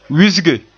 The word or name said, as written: wisge